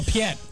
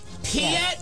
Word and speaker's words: Piet: Am I going to pronounce it wrong? Piet? – Admiral Piet. – Piet, that's right, because he's in Star Wars, and then he gets killed in Empire.